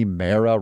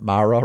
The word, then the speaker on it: Mara: It's Rooney Mara rhymes with marinara, not Mara rhymes with tiara.